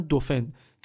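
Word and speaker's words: dauphin: بهش میگفتن دوفن که تلفظ فرانسوی دلفین هم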